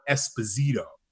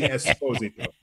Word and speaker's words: Esposito: In our country, America, we call it Esposito. – Right. – But it's really Esposito. So that's the New York pronunciation.